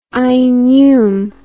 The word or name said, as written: ainhum